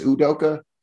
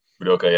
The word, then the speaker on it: Udoka: Udoka, is that's how you pronounce that one? It's not Udoka, it's Udoka. – Udoka, yeah. Ime Udoka.